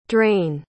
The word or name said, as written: draine